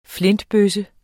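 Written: flintebøsse

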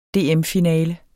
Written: DM-finale

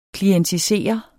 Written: klientisere

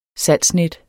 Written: salgsnet

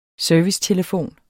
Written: servicetelefon

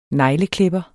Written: negleklipper

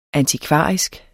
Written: antikvarisk

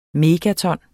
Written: megaton

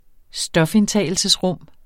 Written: stofindtagelsesrum

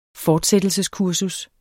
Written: fortsættelseskursus

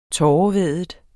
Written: tårevædet

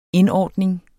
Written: indordning

